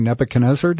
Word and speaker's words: Nebuchadnezzar: And what happens is when you're in the Word and you read stories about, like, Daniel and King Nebuchadnezzar, did I say that right? Mm-hmm.